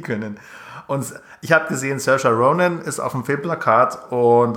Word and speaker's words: Saoirse Ronan: Saoirse Ronan. – Saoirse Ronan, genau.